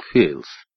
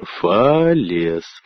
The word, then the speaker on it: Фалес: Ты неправильно произносишь, — сказал Вулф. Впрочем, как и мистер Балу. Разве не Фейлс? Нет, конечно. Напиши эту фамилию латинскими буквами, но прочти не по-английски, а произнося каждую букву. Что получится? Фалес.